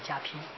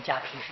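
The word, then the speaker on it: 加被: 所以要念加披，啊，披在身上要加披啊。这个台湾的发音呢是正确的啊，念加披是正确的。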